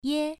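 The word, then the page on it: ye: tone 1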